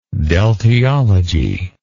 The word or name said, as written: deltiology